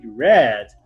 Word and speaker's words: read: For example, you write the same way the verb to read, but in the past you don't read the read, you read red.